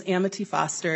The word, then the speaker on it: Amity Foster: My name is Amity Foster. It's A-M like Minnesota, I-T-Y, F-O-S-T-E-R.